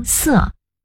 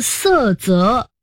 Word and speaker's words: 塞: 塞责意思是搪塞责任，塞就是堵塞的塞，跟瓶塞的塞同字，当这个字在书面语中读塞。一般成语多数来自书面语，所以大多数成语中的这个字读塞。 – 哦，读作塞责。